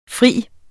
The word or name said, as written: fri